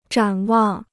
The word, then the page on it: 展望: zhǎn wàng